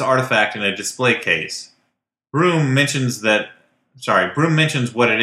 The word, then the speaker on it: Bruttenholm: The scene that has stuck with me is this moment in Hellboy where Trevor Bruttenholm, the professor... – Pronounced Broom.